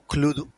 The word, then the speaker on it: Cluedo: O Cluedo, si lo quieres pronunciar bien, de en fin, pero ojo, chapó.